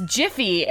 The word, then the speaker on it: GIPHY: Well, because then, like, the main app is, it's, I pronounce it Giphy, but then it's Jiffy, and that's like, no.